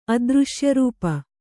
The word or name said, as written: ಅದೃಶ್ಯರೂಪ